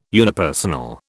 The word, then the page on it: unipersonal: British English